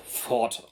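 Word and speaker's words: Fforde: Der Name wird also trotz der seltsam aussehenden Schreibweise einfach Ford ausgesprochen. Also wie der amerikanische Erfinder oder die Automarke.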